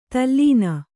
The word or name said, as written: ದಲನ